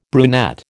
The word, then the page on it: brunette: American English